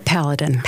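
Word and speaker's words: Palladin: I understand you first discovered this protein, is it pronounced paladin? – We call it paladin.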